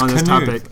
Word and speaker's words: Knuth: So I actually think it's Knuth as well. – Oh, it's Knuth. – Okay. That's what the pronunciation key says.